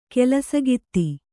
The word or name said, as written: ಕೆಲಸಗಿತ್ತಿ